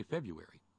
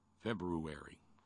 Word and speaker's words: February: What do you do with the R in February? Most people just say February. You ever try to... Ever Silent R. Lamar reminded me. There's two words that have a silent R in it, as far as I can tell. Silent R. Lamar and February. I mean, in French, it's like février, which just sounds terribly sexy. But in English, that R is a real pain in the ass. After a B and before a U.